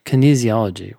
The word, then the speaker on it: kinesiology: Kinesiology.